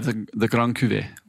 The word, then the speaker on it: Cuvée: Is that how you pronounce it? – Yeah, the Grand Cuvée.